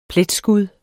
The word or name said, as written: pletskud